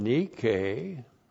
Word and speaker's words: nike: We have from the verb nikao, the noun, nike, N-I-K long E, which is the word for victory.